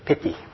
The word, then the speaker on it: piti: And I've often found it kind of odd, or I don't know what exactly, that the word, the Pali word for joy, is pronounced just like the English word pity. No, what a pity. And so pity, it's usually spelled P-I-T-I.